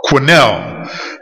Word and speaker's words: Quinnell: How do you pronounce that last name? – Quinnell. – The book is called The Mahdi, and you can write it down, M-A-H-A-D, and the A-J-Q-U-I-N-N-E-L-L.